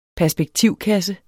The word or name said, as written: perspektivkasse